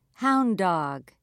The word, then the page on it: hound dog: British English